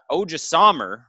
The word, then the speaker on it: Odrisamer: And Odrisamer, right? Did I say that right? Mm-hmm.